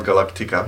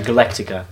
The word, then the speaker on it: Galactica: To není Battlestar, to je Battlestar, rozumíš? – Battlestar Galactica. – Ne, Galactica. Důle se tam až na druhý slabice.